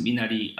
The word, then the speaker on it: Minari: Minari, and pronounce how Koreans would pronounce it, Minari.